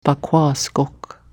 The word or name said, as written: bakwaaskok